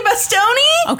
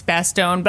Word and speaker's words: Bastone: Tough Tony Bastoni? – Okay, I'm pretty sure the last E is silent and it's Bastone, but I like Tough Tony Bastoni.